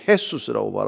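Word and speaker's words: Jesús: 그 예수아의 헬라식 표현을 예수, 뭐 남미 쪽에서는 헤수스라고 발음하고 그러죠.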